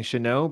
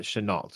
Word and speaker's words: Shenault: I know that some people have been saying Chanel, but as of me doing some research on him, it was phonetically pronounced out Chenault.